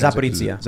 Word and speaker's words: Zaporizhia: Zaporizhia.